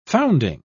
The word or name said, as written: founding